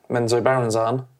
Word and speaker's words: Menzoberranzan: Menzoberranzan? – Close enough.